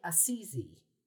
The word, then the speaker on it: Assisi: The as we said, the double S is indeed S, but the single S in this case is pronounced more like a Z. So it'd be Assisi. Think of Martin Scorsese.